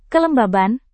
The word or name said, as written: kelembaban